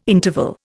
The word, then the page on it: interval: Indian